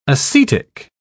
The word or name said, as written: acetic